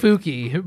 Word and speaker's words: Fuck: Is it F-U-C-K? – It's spelled F-U-C-K. It's pronounced like Fooky, but it's Fooky? Fuck. Yeah.